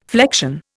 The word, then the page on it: flection: Indian